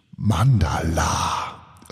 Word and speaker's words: Manndala: Und, äh, ja, und ich habe mich gerade gefragt, warum ich das Wort Mandala so komisch ausgesprochen habe. Ähm, wahrscheinlich, weil es nicht wie Mandala geschrieben wird, sondern Mandala mit Doppel-N.